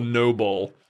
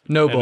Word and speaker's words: Nobull: I think the way, if I remember the conversation, is that the shoes in question are these like rainbow, they're called, the brand is called Noble. – Noble. – And it's spelled like N-O-B-U-L-L.